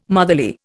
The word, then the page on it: motherly: Indian